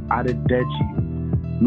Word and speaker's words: Adedeji: First name is Adedeji.